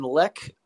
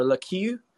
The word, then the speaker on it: Lecque: I think the only one that I liked uh, there was Jalen Leck. I think is how you say his name, or Lecu. L e c q u e.